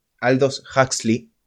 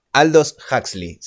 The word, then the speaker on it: Aldous Huxley: Se llama Un Mundo Feliz, es de Aldo Huxley, Aldous Huxley, perdón. – Pronuncia bien, por favor. – Aldous Huxley, se escribe A-L-D-O-S y el apellido H-U-X-L-E-Y.